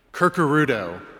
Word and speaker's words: Curcuruto: Kind of like when people see my family name, Curcuruto.